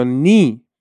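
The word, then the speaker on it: knee: تلفظ کردن حروف سایلنت حالا یعنی چی تمام حروفی که نوشته میشن تلفظ نمیشن باز شما باید چکار کنی بری فونتیک اون کلمه رو بخونی تا نخونی که متوجه نمیشی مثلا نو به معنای دانستن یا نی به معنای زانو خب یه دونه کی اولش داره که سایلنته یا نایف چاقو یه کی اولش داره که سایلنت.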